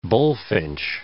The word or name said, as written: bullfinch